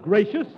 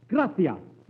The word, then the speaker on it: gracias: No, not gracious. That word is gracias. You see, in Spanish, the C is pronounced T-H. Gracias. You see? T-H. You see? – Would you mind putting your head some...